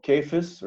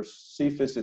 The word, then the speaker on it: Cephas: Then there is Peter, who is here called Cephas, or Cephas it's sometimes pronounced.